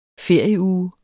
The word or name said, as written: ferieuge